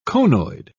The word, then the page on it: conoid: British English